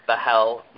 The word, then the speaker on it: Bahl: My name is Viom Bahel.